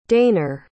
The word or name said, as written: dayner